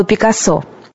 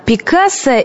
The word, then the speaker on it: Пикассо: А кстати, как правильно, Пикассо или Пикассо? Заглянем в Большую советскую энциклопедию, и вот первый парадокс от загадочного художника. Редкий случай, когда строгие редакторы признают правильным два ударения. Одно – испанское на втором слоге, второе – французское на последнем.